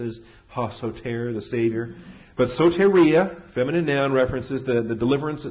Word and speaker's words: soteria: Vocabulary is soteria, S-O-T-E-R-I-A. It's the long O and long E. The omega and the eta. S-O-E-T-E-R-I-A, soteria.